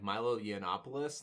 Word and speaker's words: Milo Yiannopoulos: Milo Yiannopoulos, that guy, remember him? – Congrats on pronouncing his name correctly on the first try.